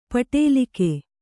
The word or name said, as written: ಪಡಲಗೆ